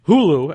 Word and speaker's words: Huglu: Spelled H-U-G-L-U. Hulu, actually, turns out to be the correct pronunciation.